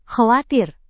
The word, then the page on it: khawatir: Indonesian